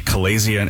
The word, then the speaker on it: chalazion: Chalazion. It's C-H-A-L-A-Z-I-O-N, which is slightly different.